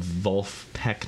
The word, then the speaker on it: Vulfpeck: Yes, it's like Wolfpack, but in German. – Ah, okay. You seem to have some familiarity. You seem to have smuggled some Vulfpeck knowledge into our lives.